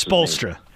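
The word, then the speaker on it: Spoelstra: Polestra? I can never pronounce Spolstra.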